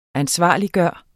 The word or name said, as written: ansvarliggøre